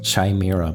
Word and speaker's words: Chimera: I think it's pronounced Chimera, by the way. – Okay, Chimera. Chimera, of course.